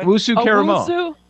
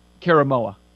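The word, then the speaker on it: Owusu-Koramoah: Owusu Karamoa. – Owusu Karamoa? – Karamoa.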